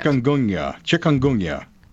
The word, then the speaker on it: Chikungunya: Chikungunya.